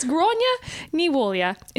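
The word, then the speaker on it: Gráinne Ní Mháille: So, it's Gráinne ní mhólia is how you say her name, but it's spelt G-R-A with an accent, I-N-N-E, N-I with an accent, space, M-H...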